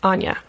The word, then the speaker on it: Anja: And you like, just for listeners, like, basic storyline, you have Anja. Am I pronouncing that right? – Anya. – Anya, yeah. Sorry. So, I'm thinking it rhymes with ganja.